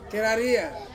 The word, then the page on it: keraria: Ansus